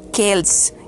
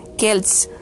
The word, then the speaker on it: Celts: En realidad se debe pronunciar, se dice en inglés kelts, kelts, Kelts.